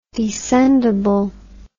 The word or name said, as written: descendability